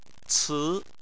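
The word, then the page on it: ci2: tone 2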